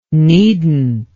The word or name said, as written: neednt